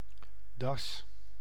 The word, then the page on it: necktie: American English